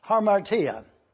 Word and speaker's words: hamartia: The first word is a Greek word, which is spelled H-A-M-A-R-T-I-A, harmartia.